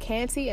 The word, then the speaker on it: Kante: You also can follow me on Facebook at Fanta Kante, and it's spelled F-A-N-T-A, last name K-A-N-T-E.